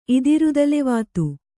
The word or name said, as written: ಇದಿರುದಲೆವಾತು